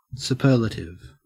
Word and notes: superlative: Australian English

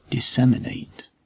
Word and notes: disseminate: British English

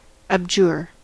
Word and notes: abjure: American English

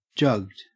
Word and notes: jugged: Australian English

